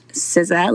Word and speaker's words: SZA: So SZA is her stage name, and it's pronounced SZA, like scissor.